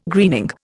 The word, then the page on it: greening: Indian